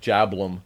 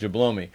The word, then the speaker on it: Jablome: The last name is spelled J-A-B-L-O-M-E. It's like Jablum. I think they probably say Jablum and they spell it for him, you know, Haywood Jablum. But when you read it, it looks like Haywood Jablomi.